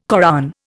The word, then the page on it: Koran: Indian